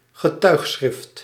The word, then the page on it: getuigschrift: [ɣəˈtœy̯xˌsxrɪft]